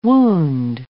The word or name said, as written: wound